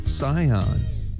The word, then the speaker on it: Sihon: 먼저 시혼을 영어로 이렇게 발음합니다. 잘 듣고 따라해보세요. Sihon, S-I-H-O-N, Sihon 이렇게 발음해 주시고 그모스는 C-H-E-M-O-S-H입니다.